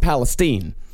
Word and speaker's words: Palestine: In fact, in Texas, it's pronounced Palestine. – Well, of course, because Palestine is how they would say it in the Middle East. And we're different here in Texas.